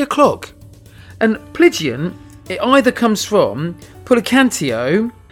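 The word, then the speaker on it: Plygain: But what it's called is, and I'm probably going to say this wrong, it's spelt P-L-Y-G-I-A-N, which I believe is Plygian.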